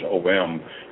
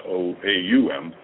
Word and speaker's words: Om: It's all about the sacred numbers, and then that sound, that om, you know, that we pronounce it's om, is actually sounds aum.